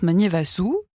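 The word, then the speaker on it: Magnevasoa: Puis Juliette Magnevazoa, S-O-A, et ça se prononce Magnevasu, vraisemblablement.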